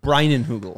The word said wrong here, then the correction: Leinenkugel: I always say it like Jacob Leinenkugel Brewing Company. Jacob Leinenkugel Brewing Company. Jacob Leinenkugel Brewing Company. – There you go.